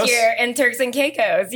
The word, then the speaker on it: Caicos: Which I did not know how to pronounce for the longest time. – Turks and Caicos. – I was like, cake?